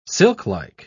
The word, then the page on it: silklike: British English